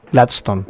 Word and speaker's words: Gladston: Pero Glaston, la primera pregunta es, ¿cómo se pronuncia de verdad tu nombre? – Gladstone.